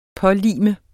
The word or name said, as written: pålime